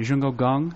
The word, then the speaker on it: Jungho Gong: Jungho Gong, does that sound right? Is that close? – That is close.